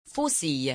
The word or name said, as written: faucille